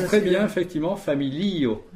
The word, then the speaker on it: familio: Quand on dit yes, on est, pour dire oui, on écrit J-E-S. Et, mais je pense qu'en donc en espéranto, il faut bien dire familio, puisque la dernière syllabe, c'est le O tout seul, et l'avant-dernière syllabe, c'est le I.